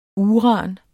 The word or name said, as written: uran